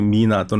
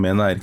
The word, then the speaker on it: MENA: 그래서 음, 보통 서양권에서 MENA 해가지고 미나 또는 메나 이렇게 아, 발음하거든요.